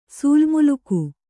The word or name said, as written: ಸೂಲ್ಮುಲುಕು